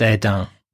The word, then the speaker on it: Hesdin: First stop was a lovely small town by the name of Hesdin, pronounced Edin, where we had some delicious chips.